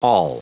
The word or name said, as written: all